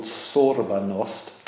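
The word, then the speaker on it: sobornost: He's pointed to a word that comes from the Russian tradition called sorbanost.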